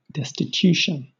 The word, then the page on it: destitution: British English